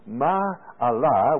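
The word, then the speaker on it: Ma'alah (מעלה): In the Hebrew tongue, Ma'alah, which is spelled M-A-A-L-A-H, Ma'alah, a journey to a higher place.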